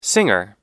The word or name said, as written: singer